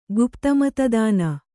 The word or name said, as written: ಗುಪ್ತಮತದಾನ